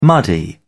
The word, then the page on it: muddy: British English